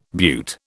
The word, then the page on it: beaut: British English